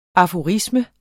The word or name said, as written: aforisme